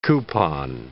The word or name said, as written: coupon